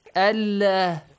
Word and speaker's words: Allah: Whenever Fatha or Dhamma comes before the word Allah, you have to pronounce it Allah heavily. But if Kasra comes before it, Allah.